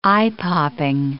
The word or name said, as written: eye-popping